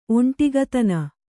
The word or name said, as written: ಒಂಟಿಗತನ